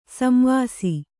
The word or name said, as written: ಸಂವಾಸಿ